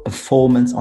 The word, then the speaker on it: performance: So management on the first syllable, performance on the second syllable, for example.